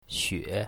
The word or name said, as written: xue3